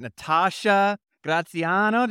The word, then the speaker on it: Natasha Graziano: Natasha Graziano, did I say it right? – You did.